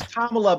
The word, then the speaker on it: Kamala: Isn't it Kamala? I thought it was Kamala. – It is Kamala, but...